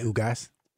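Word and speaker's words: Ugás: And of course, this past weekend, Rob G, Manny Pacquiao took on, I don't even want to mess up his name, Yordanes Ugas. Did I say that right? – That's close enough. You could just say Ugas.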